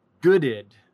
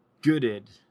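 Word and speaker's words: Goodeid: The family is named after a man named Goad, maybe? So it should really be pronounced Gooded. Ah, without the E. Gooded.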